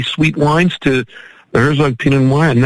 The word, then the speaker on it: Pinot Noir: So we had the Herzog Lineage Pinot Noir. Is that the right pronunciation? Pinot Noir? – Yes.